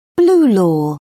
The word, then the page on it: blue law: British English